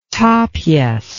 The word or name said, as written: Tapies